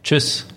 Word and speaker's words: Tschüss: Tschüss.